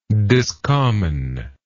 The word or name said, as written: discommon